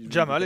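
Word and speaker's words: Jamaal: J'ai peur d'écorcher son prénom, mais c'est Jamal. Hein, si Jamal, exactement.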